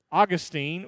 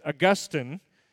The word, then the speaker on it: Augustine: One of the early church fathers, Augustine, or as theologians pronounce it, Augustine, he once wrote about God, "You have made us for yourself, and our heart is restless until it rests in you."